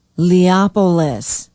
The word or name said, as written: Leopolis